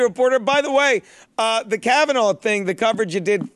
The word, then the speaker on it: Kavanaugh: It's pronounced Kavanaugh.